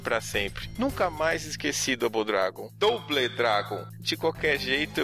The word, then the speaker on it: Double Dragon: Nada de Double, é Double Dragon.